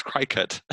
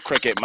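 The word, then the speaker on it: Cricut: Is that how you say it? Because I thought it was cry cut.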